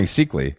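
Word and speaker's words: Seikaly: Seekley, is that how you pronounce it? – I think it's just Ronnie Seekley.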